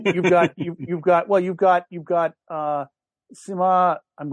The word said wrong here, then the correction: Simu Liu: Simu Liu.